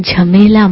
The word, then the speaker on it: jhamela: So we will become jhamela mukta, free of jhamela. Why Baba is saying this? Because the diamond jubilee is coming. Actually, it's like the word rhyming with mela.